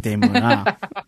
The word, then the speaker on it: denouement: There's usually, you know, like a denouement, as they call it, which is the moment after the climax in the movie. And this one... – Not just climax when you said denouement.